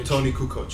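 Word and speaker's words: Toni Kukoč: Tony Kukoc, yeah.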